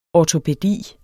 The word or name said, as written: ortopædi